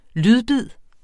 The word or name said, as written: lydbid